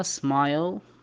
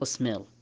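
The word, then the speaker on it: smile: هذا بالخطوة الأولى أن الآي ينطق بحرف الآي إذا أتى الحرف الثاني اللي هو حرف الإي، مثل الكلمة الأولى سمايل، الكلمة الثانية ماين، الكلمة الأخيرة واير. ما ينطق وير ولا ينطق مين ولا ينطق سميل.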